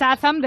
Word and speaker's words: Shazam: Shazam 0. Shazam es una app para saber la canción intérprete que escuchas en un momento determinado. me, me, encanta tu pronunciación porque es Shazam de toda la vida.